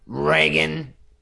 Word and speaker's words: Reagan: The popular pronunciation is Reagan. – Okay. Like ray gun? Like I've got a gun that shoots some sort of heat-seeking laser ray gun? – Like a ray gun.